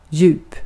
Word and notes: djup: /jʉːp/

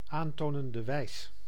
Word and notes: aantonende wijs: /an.ˈto.nen.də ˈwɛjs/

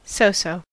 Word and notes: so-so: American English; [səʊ.səʊ]

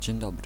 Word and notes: dzień dobry: [ʥɛɲ ˈdɔbrɨ]; /d͡ʑɛɲ ˈdɔbrɨ/

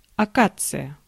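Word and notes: акация: Russian; akatsija

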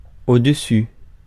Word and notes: au-dessus: France; [o.dø.sy]

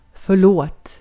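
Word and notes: förlåt: Swedish

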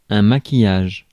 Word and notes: maquillage: France; [ma.ki.jaʒ]

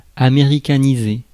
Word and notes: américaniser: France; /a.me.ʁi.ka.ni.ze/

